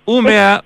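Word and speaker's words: Umeå: UMEA Universitet en Suecia.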